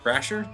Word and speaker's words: Brasher: We're talking with Jesse Brasher.